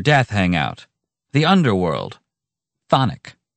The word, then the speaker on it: chthonic: Then I give you thonic, spelled, and I kid you not, C-H-T-H-O-N-I-C. – Sounds more like what a Dudley Moore character likes with his gin. – Well, the first C is silent. – And not to mention the first H.